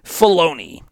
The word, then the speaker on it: Filoni: the great mind of Dave Filoni.